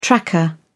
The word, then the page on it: tracker: British English